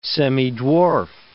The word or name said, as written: semidwarf